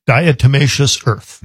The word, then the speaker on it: diatomaceous earth: Diatomaceous earth.